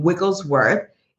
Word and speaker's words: Wigglesworth: So again, I have a crazy name, you know, like, as you say, the Harry Potter-esque name, Wigglesworth. It's only three syllables, but most people get it wrong.